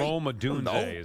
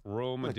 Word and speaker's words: Odunze: Rome Odunze is how you say his name. Thank you, Rob, for looking that up. Rome Odunze.